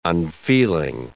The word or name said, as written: unfeeling